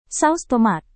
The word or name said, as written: saus tomat